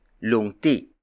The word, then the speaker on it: lungti: The first word is lungti.